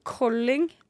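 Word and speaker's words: Kolding: men det uttales Colling, tror jeg. Colling, ja.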